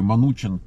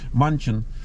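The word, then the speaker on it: Manchin: То есть вот там такой странный, ну, лоббист угольной индустрии от демократической партии, от штата Западная Вирджиния, такой Манучин. Вообще пишется Манчин, Манухин как бы, да, по-нашему. Манчин, вот Манучин они его называют.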